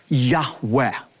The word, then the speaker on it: Yahweh: feel is pronounced a breathy Yahweh.